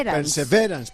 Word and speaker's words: Perseverance: Entonces ahora será Perseverance. – Eso es, Perseverance, perfecto.